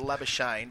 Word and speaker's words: Labuschagne: There's going to be many ways of pronouncing his name, but we're going to go with Labuschagne because that's what he's asked for. If you can't use the Afrikaans hard R, then don't use it at all.